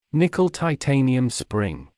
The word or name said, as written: nickel-titanium spring